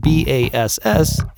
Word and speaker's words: bass: But it's important to remember that when discussing music, the word spelled B A S S is pronounced bass.